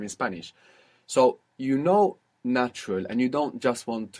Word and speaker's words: natural: So you pronounce a T. A is like A, U is like U, natural. Very good. And the accent on the end as well. It's natural.